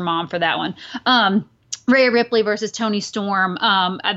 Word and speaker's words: Rhea Ripley: Um, also, Ray Rip- Ray Ripley. the excuse me, Rhea Ripley.